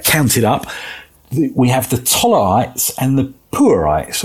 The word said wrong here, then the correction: Punites: or Punites is how it's said.